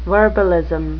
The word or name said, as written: verbalism